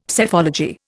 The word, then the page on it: Psephology: Indian